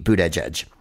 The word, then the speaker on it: Buttigieg: Yes, his name is Pete Buttigieg.